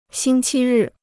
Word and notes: 星期日: xīng qī rì

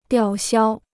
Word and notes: 吊销: diào xiāo

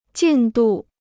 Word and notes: 进度: jìn dù

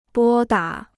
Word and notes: 拨打: bō dǎ

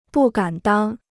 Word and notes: 不敢当: bù gǎn dāng

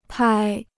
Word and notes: 拍: pāi

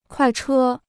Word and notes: 快车: kuài chē

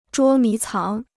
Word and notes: 捉迷藏: zhuō mí cáng

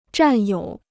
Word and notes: 占有: zhàn yǒu

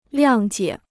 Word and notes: 谅解: liàng jiě

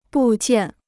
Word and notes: 部件: bù jiàn

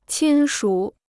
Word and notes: 亲属: qīn shǔ

